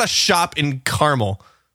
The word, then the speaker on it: Carmel: Here in California, there's a city called Carmel, and it's spelled C-A-R-M-E-L, Carmel, and so I feel kind of bad not saying caramel.